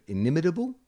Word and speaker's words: inimitable: I can never say this word, inimitable. – Yeah, you can say that word. Keanu That's how you say it. Inimitable. Inimitable uh, The Matrix and John Wick.